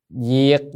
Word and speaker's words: diệt: Diệt giả, có khi là đọc dịch, nhưng mà hách dịch thì người ta tưởng lầm nghĩa nên đọc là diệt.